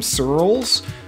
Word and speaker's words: Searls: My Twitter handle is my last name, Searls, which phonetically spelling it is difficult. So it's like pearls, but with an S instead of a P.